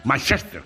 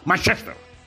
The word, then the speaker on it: Manchester: Manchester, con acento en la primera E y arrastrando la CH. Repite conmigo, Manchester.